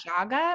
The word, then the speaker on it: chaga: And shaga. – Am I saying that right? Yeah. C H A G A mm-hmm.